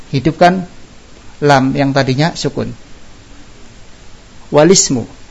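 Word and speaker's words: walismu (والاسم): Nah ini yang dikatakan hamzah wasal di tengah kata atau pertengahan antar kata dia hilang, nggak kebaca. Alismu dan walismu. Yang bacanya alismu atau walismu salah.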